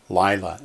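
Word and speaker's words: Lila (לילה): That one accents on the first syllable. Lila, it means night.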